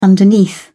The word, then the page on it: underneath: British English